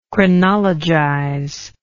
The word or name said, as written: chronologize